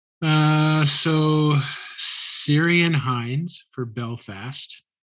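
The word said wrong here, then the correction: Ciarán: It's pronounced Kieran. Kieran. Yeah.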